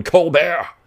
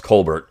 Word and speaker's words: Colbert: Now, it's Colbert, of course, but Colbert is how you would say his name.